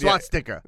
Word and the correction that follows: swastika: Nope, it's swastika. – Swastika. – Yeah. – Well, I was saying SWAT. – SWAT. – There's no T.